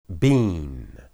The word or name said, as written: bin